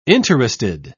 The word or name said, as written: interested